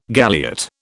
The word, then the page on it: galliot: British English